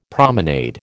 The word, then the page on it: promenade: American English